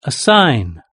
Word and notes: a sign: British English

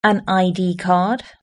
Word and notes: an id card: British English

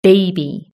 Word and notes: baby: British English

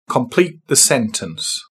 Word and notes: complete the sentence: British English

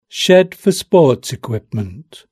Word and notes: shed for sports equipment: British English

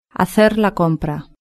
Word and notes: hacer la compra: European Spanish

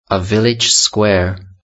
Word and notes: a village square: British English

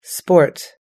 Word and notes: sport: American English

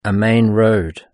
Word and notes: a main road: British English

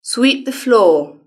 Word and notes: sweep the floor: British English

